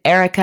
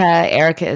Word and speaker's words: Erykah: So you can find me, first of all, the name of my podcast is The M Word with Erica. Erica is spelled E-R-Y-K-A-H.